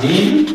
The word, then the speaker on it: nihil: Dar în interiorul limbii latine, în interiorul cuvintelor pronunțate, în interiorul cuvintelor care le conțineau pe H, avem dovada că H nu se pronunța, cea mai cunoscută dovadă este dată de adverbul nihil, de fapt un pronume. Categoria așa gramaturală de pronume, negativ, care se pronunța nil, nil,